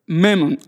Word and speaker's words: Mammon: Mammon, I don't know in English, how do you say the word? Mammon? Mammon? Oh, how? Mammon, okay, Mammon.